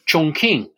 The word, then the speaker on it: Chongqing: Remember that during the war against Japan, about which we've spoken, that long, bitter conflict, the nationalist capital was in Chongqing, as it's pronounced correctly.